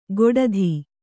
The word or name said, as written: ಗೊಡಧಿ